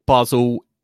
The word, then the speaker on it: Basel: How do I say it? Basel. – Basel is correct.